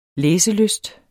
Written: læselyst

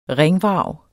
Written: ringvrag

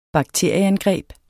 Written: BAKTERIER

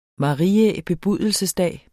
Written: marias

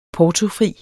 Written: portofri

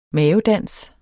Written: mavedans

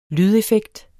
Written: lydeffekt

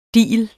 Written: digel

